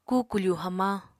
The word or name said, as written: kukufu hamañ